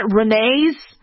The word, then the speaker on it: Rennes: Okay, it's spelled R-E-N-N-E-S. So is that Rennes? Rennes?